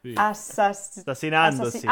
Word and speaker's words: assassinandosi: A ver, entonces es 66 assassini andarono, jo, me estás usando el pasado, andarono, esa la pronuncia bien, Assisi, tutti e 66 assassinatosi. Dosi, no? No. – Assassinandosi. – Ah, vale, assassinandosi. – Sì.